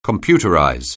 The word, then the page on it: computerize: anglais